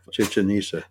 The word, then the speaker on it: Chichen Itza: Chichen Itza. – There you go.